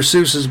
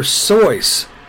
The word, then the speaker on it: Seuss: According to friends and family, the name rhymes with voice, not Deuce. So Dr. Seuss, not Seuss. Isn't that crazy? Everybody says Dr. Seuss. It's Dr. Seuss, according to his own family.